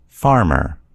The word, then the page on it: farmer: American English